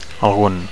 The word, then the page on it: algun: Catalan